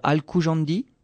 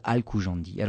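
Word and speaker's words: al-Khujandi: sûr que c'est écorché, parce que le KH en arabe, c'est pas, c'est pas que. Hein. Donc on, on, va dire, on va le prononcer à l'occidental, Al-Koujandi.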